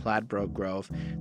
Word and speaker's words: Ladbroke Grove: I didn't even say it right this time. Ladbroke Grove.